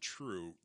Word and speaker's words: Trve: beer of the week is, uh, I believe they pronounce it True Brewing. We've had them on. It's TRVE Brewing Company.